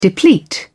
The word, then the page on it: deplete: British English